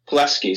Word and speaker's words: Pulaski: This is in Kentucky, but it's actually in is it Pulaski County? – Pulaski is how you say it.